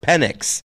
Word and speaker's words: Penix: No, the best part is the way it's pronounced is his last name is pronounced Penix.